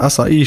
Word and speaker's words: Açaí: Die kommt nämlich von Sari, die sagt, das Wort Açaí stammt aus dem Brasilianischen, wo die Betonung auf dem I liegt.